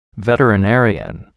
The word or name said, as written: veterinarian